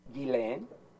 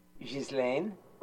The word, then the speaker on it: Ghislaine: But I'm betting, the name, Ms. Maxwell's name can be pronounced Ghislaine. It can be pronounced Ghislaine. And there's probably a couple other pronunciations that can be acceptable, in certain linguistic settings and whatnot. But I think she uses Ghislaine,